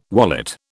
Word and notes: wallet: British English

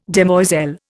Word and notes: demoiselle: Indian

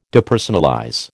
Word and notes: Depersonalize: American English

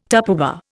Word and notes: Tupperware: Indian

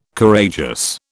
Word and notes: Courageous: British English

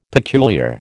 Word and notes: peculiar: American English

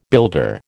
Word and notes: builder: American English

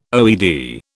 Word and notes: OED: British English

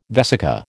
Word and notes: vesica: American English

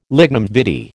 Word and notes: lignum vitae: American English

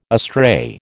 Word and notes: astray: American English

